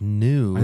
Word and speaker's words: gnu: Well, it's spelled G-N-U. It's a gnu. Or a gnu? Is it pronounced gnu or new? – I guess it would just be pronounced new. – I think it's new.